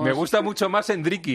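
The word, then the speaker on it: Endrick: Oye, has dicho Hendrik, para que aprendamos ya a pronunciarlo, es Hendrik, ¿no? – Sí, es Hendrik. Aquí todas las vocales al final se dice una I al final. Entonces, aquí dicen que es Hendrik.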